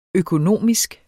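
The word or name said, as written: økonomisk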